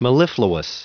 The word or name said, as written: mellifluous